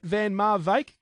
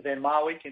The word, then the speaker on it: Van Marwijk: So it's along those lines, but he understands that all the English-speaking countries pronounce it Van Marwijk, and he's happy with that. – Right, okay. So Van Marwijk, Van Marwijk, somewhere there or in between is okay.